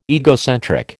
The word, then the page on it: egocentric: American English